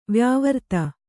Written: ವ್ಯಾವೃತ್ತಿ

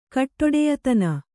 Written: ಕಟ್ಟೊಡೆಯತನ

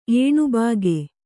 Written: ಏಣುಬಾಗೆ

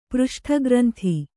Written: ಪೃಷ್ಠಗ್ರಂಥಿ

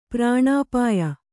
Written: ಪ್ರಾಣಾಪಾಯ

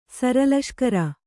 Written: ಸರಲಷ್ಕರ

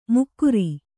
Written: ಮುಕ್ಕುರಿ

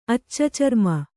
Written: ಅಚ್ಚಚರ್ಮ

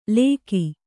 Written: ಲೇಕಿ

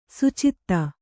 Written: ಸುಚಿತ್ತ

